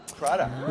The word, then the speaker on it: Prada: Miuccia Prada? – Prada.